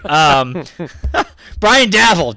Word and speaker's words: Daboll: I'm just impressed that you finally, after all season, pronounced Dable's name correctly. – I didn't. It took us all year long. I wish I hadn't, because that's kind of my thing, not to pronounce them right. But anyway, Brian Daboll, damn it.